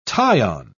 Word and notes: tie-on: British English

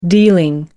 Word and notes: dealing: American English

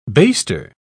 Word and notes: baster: British English

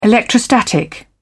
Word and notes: electrostatic: British English

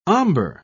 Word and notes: ombre: British English

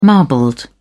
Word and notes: marbled: British English

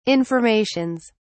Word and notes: informations: British English

